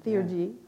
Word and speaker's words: Theurgy: Theurgy, is that right? Theurgism. – Theurgy. – Yeah.